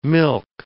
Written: milch

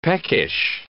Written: peckish

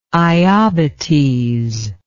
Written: iobates

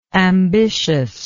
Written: ambitiousness

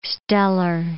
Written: stellar

